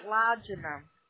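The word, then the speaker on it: Laedina: No. Pronunciation Lajna.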